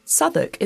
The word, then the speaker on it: Southwark: Southwark is not spelt remotely as it's pronounced. Drop the W when you say it, or check the notes.